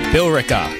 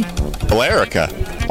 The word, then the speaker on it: Billerica: Bill Eric. It's Billerica? Bear and Bill? What? How do you pronounce that? Billerica. There's an E in there. Billerica. Billerica, maybe? – Billerica. – Oh, that makes sense. Just leave out the E.